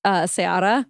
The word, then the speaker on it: Ceará: Fun fact, like C-E-A-R-A. It's pronounced Ceará, but it's like a place in Brazil.